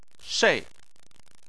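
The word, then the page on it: shei: tone 4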